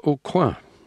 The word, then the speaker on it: Aucoin: The drummer Michael Brothers, the pianist Andy Navala, and the bass player Tim Aucoin. I'm pronouncing that in the French way because he's a New Orleans native, although he lives elsewhere now.